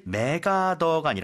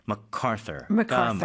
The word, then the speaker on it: MacArthur: 사실 맥아더가 아니라 원래 발음은 MacArthur가 맞겠죠.